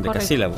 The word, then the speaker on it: decasílabo: ¿Cómo es? ¿Un decasílogo? – Sí, un decasílabo, correcto.